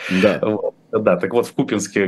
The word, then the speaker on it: Купинске: обращаю внимание, что Купинске надо ударение делать на первом слоге, чего бы никогда не узнали, если бы героическое, не героическое поведение российских войск на минувшей неделе.